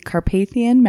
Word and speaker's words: Carpathian: Okay, so she was born in 1560 in the Carpathian Mountains. Is that how you say it? – Yeah, I believe that is correct.